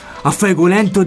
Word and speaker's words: A fuego lento: È una scelta, quindi è A fuego lento di Rosana. – L'ho detto bene? – Sì.